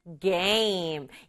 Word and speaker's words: game: 우리말로도 게임, 영어로는 game. 이것도 마찬가지로 우리말은 게임 두 박자지만 영어는 game. game 이렇게 한 박자로 발음해줘요.